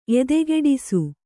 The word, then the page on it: ಎದೆಗೆಡಿಸು: kannada